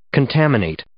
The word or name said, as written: contaminate